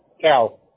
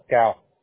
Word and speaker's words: Kal: Cal. K-A-L. Cal.